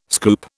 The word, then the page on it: scoop: British English